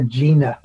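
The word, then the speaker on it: Jena: and the Jean Band of Choctaw Indians. Am I saying that right? – It's the Gina.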